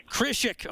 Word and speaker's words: Krzysik: Kreischik, am I doing that right? – Kreischik.